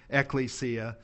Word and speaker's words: ekklesia: So church is the Greek term ekklesia or ekklesia, depending on who you listen to pronounce the word. The Greek term is ekklesia.